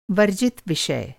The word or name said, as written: वर्जित